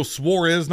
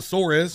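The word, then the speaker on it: Suárez: Nobody can say his name right. It's Daniel Suarez, not Suarez.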